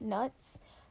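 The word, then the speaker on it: Knut: And it's spelled K-N-U-T-S, so I'm going to pronounce it nuts, but I know some people say knuts, but I don't think that sounds as good.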